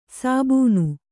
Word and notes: ಸಾಬೂನು: sābūnu

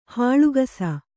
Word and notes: ಹೊಳಕಿಸು: Kannada

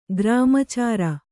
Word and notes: ಗ್ರಾಮಚಾರ: kannada

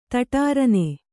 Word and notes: ಧಡಾರನೆ: kannada

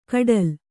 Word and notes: ಕಡಲ್: kaḍal

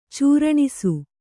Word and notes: ಚೂರಣಿಸು: cūraṇisu

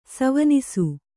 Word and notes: ಸವನಿಸು: savanisu

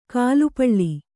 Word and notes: ಗಾಲಿಪಲ್: kannada

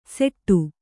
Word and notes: ಸೆಟ್ಟು: kannada; seṭṭu

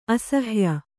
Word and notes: ಅಸಹಾಯೆ: asahāye; kannada

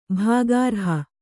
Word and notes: ಭಾಗಾರ್ಹ: bhāgārha

